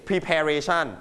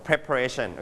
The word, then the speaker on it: preparation: You don't say preparation, no, preparation, okay? – Preparation.